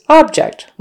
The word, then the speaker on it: object: The noun is object, with a stress on the first syllable, object.